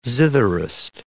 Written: zitherist

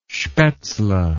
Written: spatzle

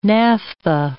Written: naphtha

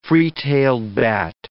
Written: free-tailed bat

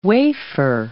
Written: wafer

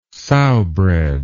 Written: sowbread

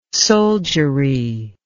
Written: soldiery

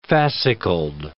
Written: fascicled

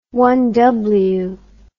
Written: one-upmanship

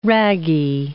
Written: ragi

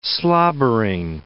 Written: slobbering

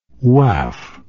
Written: waaf

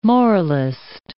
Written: moralist

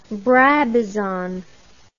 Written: brabazon

